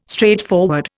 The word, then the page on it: straightforward: Indian